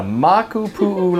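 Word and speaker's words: Makupu'u (Makapuʻu): The Makupu'u Lighthouse. You said it right.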